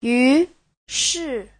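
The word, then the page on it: yushi: yu2shi4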